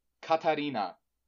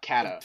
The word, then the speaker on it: Katarina: Yeah, which is exactly Katarina. How it's said in Japanese too. – Yeah. – It's just follow the simple phonetics. – Yeah. – Katarina. It's just not Kata. – Fighting Kata. Over romanizing Kata.